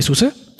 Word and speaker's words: SUSE: But I need to be sure that you first know how to pronounce this word, because there is a lot of confusion. It is SUSE. I would like that you repeat with me, so to be sure that you can pronounce this. SUSE.